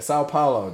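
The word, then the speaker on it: São Paulo: Sao Paulo, dog.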